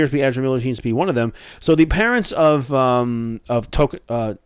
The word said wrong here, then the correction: Tokata: did I say her name wrong? Was it, I said Toka, is it Tokata? Yeah. Tokata.